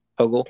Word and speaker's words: Hochul: Hogle.